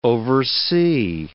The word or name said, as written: oversea